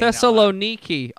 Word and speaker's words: Thessaloniki: Thessaloniki. – Can I see it? – Tessa. It's the very bottom. – Thessaloniki.